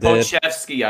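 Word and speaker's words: Pokuševski: I don't know if I said it right. I'm not like I did. Pokusevsky, I think it is. – All right, that sounds even better.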